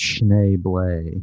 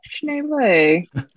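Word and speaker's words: Schneebly: Actually, it's pronounced Schneeblay. – Schneeblay.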